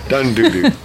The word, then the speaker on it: Dundudu: Well, D-U-N-D-U-D-U. How would you say it? – Sounds like that. – Okay. I don't want to foul up the pronunciation. – I like Dodo. – There was no words, hey, so I never heard it. Dodo? D-U-D-U? – Doo-doo. I'm sorry. Yeah. – Yeah. – That's right. – Dun-doo-doo.